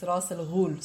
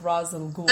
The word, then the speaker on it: Ra's al Ghul: I mean, in Arabic directly, it's Ra's al Ghul, so it's like Ra's al Ghul. – Ra's al Ghul is the correct pronunciation.